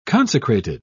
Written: consecrated

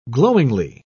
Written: glowingly

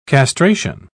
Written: castration

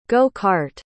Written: go-cart

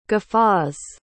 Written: guffaws

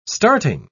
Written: starting

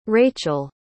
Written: racheal

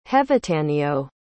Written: hevataneo

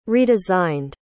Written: redesigned

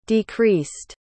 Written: decreased

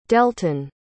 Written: delton

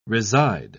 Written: reside

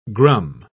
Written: grum

